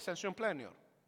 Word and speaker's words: sensus plenior: Es una palabra que ni se preocupe cómo se pronuncia ni cómo se escribe. Pero se la voy a decir, es sensium plenior.